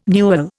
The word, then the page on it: newel: Indian